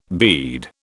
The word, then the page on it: bead: British English